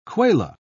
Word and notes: kwela: British English

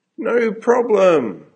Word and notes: no problem: American English

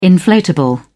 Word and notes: inflatable: British English

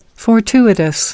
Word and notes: fortuitous: American English

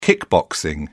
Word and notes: kickboxing: British English

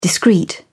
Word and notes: discreet: British English

